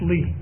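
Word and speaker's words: Li: He was a Chinaman, spelt his name with L-I, but pronounced Lee, Charlie Lee.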